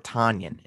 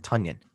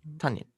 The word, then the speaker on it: Tonyan: That's actually how you say it, by the way. – Yes, I heard. I heard it's not Robert Tonyan. It's Robert Tonyan. – Yeah, like John Runyon. – Except it's spelled differently. Funyan. – Yes, or Funyan. Or Funyan. – That's yeah. That's the way I saw it. Was pronounced Funyan and yes. Then add the T. Tonyan.